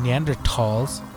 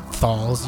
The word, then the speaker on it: Neanderthals: I read an article that stated researchers found archaeological evidence in Spain that ancient human species, Homo antecessor and Neanderthals, you don't pronounce it thals, you dumb ingrates.